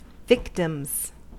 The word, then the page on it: victims: American English